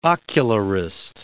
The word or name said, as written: ocularist